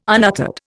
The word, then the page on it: unuttered: Indian